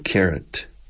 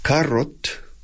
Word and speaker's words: carat: It's interesting, carat. Now, carrot, lo que se come, is exact, the pronunciation is identical. Carat. Carrot. Yeah.